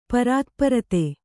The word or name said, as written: ಪರಾತ್ಪರತೆ